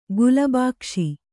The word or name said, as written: ಗುಲಬಾಕ್ಷಿ